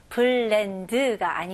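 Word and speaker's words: blend: 이거는 믹스하고 똑같이 이름절로 발음하셔야 돼요. 블렌드가 아니고 블렌드.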